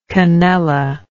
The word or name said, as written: canella